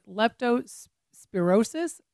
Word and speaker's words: leptospirosis: leptospirosis.